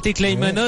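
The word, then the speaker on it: Teklehaimanot: Vous l'avez entendu, donc il y a trois hommes en tête, donc dont l'Érythréen Tekleimanot. On a appris à le prononcer, c'était pas facile de début du tour, mais on commence à l'avoir bien en bouche. Tekleimanot qui est passé tout à l'heure au sommet de la petite côte, la première...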